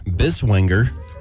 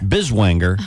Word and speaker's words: Biswanger: This woman, her name is Carlene Biswanger. – What? Yeah. Sorry? What was it? – Carlene Biswanger. – Biswanger. – Okay. Mm-hmm. – Spell it for me. – B-I-S. Uh-huh. W-A-N-G-E-R.